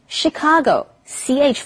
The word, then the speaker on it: Chicago: 这些都是 CH 发 CH 的音。不过因为 Chicago 这个字是从法文来的，所以要念成 Chicago。CH 发成 SH 的音，所以不要念错咯。我们偶尔还是会听到有人念错。类似的字还有像机器 machine, 主厨 chef, 香槟 champagne, 还有像简介的小册子是 brochure, 这些字都是 CH 发成 SH 的音。